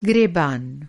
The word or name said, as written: गरीबान